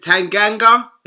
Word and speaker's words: Tanganga: Special shout out to, is it Tanganga? Is that how you pronounce his name? – Yeah.